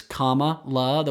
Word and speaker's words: Kamala: For those of you that have been saying it wrong, it's Kamala. The way to think of it is it's like Mama-la, because that's what her stepkids call her.